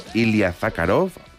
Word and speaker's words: Ilya Zakharov: El saltador ruso Ilya Zakharov, a ver si lo he dicho bien. – Lo has dicho bien.